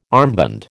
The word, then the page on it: armband: American English